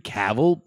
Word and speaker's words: Cavill: And I think Henry Cavill played a nice...